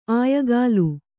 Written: ಆಯಗಾಲು

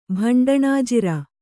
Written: ಭಂಡಣಾಜಿರ

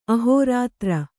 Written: ಅಹೋರಾತ್ರ

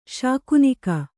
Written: ಶಕುನಿಗ

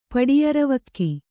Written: ಪಡಿಯರವಕ್ಕಿ

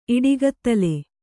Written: ಇಡುಗತ್ತಲೆ

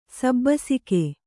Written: ಸಬ್ಬಸಗೆ

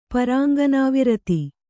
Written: ಪರಾಂಗನಾವಿರತಿ

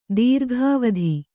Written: ದೀರ್ಘಾವಧಿ

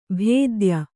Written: ಭೇದ್ಯ

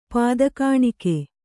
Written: ಪಾದಕಾಣಿಕೆ

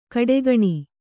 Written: ಘಟಾಗ್ನಿ

